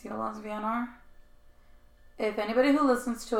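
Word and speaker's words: Jólasveinar: You can pronounce, I found the pronunciation is Yolas Vinar, which when you see the thing, it looks like Veen, but to me, it might actually be Vien, Yolas Vinar.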